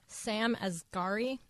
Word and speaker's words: Sam Asghari: Sam Asghari, I believe that's how it's pronounced.